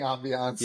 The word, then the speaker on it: ambiance: I like saying ambiance. – Yeah, no, that's how you pronounce it.